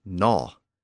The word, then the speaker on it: gnaw: And over time, that awkward G sound at the front also disappeared, and it became simply gnaw. Of course, we still spell it with that G at the beginning, G-N-A-W, because the initial G was once pronounced.